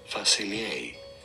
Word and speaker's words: Facilier: It is generally pronounced as Dr. Facilier. Dr. Facilier from French.